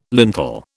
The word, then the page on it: lintel: British English